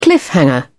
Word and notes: cliffhanger: British English

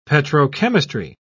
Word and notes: petrochemistry: British English

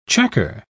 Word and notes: chequer: British English

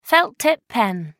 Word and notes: felt-tip pen: American English

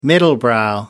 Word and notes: middlebrow: American English

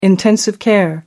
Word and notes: intensive care: American English

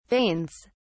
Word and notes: veins: British English

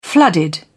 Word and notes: flooded: British English

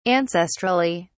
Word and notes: ancestrally: British English

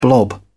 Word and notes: blob: British English

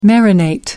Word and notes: marinate: British English

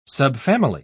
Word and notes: subfamily: British English

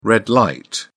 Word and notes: red-light: British English

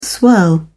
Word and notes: swirl: British English

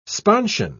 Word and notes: sponsion: British English

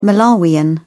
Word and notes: malawian: British English

